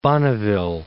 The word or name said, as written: Bonneville Salt Flats